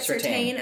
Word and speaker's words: Surtain: Like, Patrick Surtain. Did I say that right? – Surtain. – I'm sorry, Patrick. Patrick Surtain.